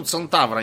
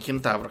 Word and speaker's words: Центавра: Вот, кстати, почему Центавра, а не Кентавра, как вообще правильно говорить? В астрономии принято через С все это озвучивать.